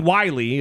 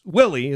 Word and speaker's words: Willey: And Kathleen Wiley, it's Willie is her name. – Oh, I was pronouncing it Willie earlier. – No, it's Kathleen Willie. – Oh, it is Willie? – Yeah, it's Willie, not Wiley. She said Wiley.